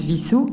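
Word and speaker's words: Wissous: Il y a un W, mais ça se prononce Vissou.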